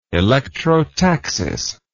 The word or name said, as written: electrotactic